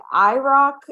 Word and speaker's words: IROC: Is there, like, a, do you say it like IROC? Yeah.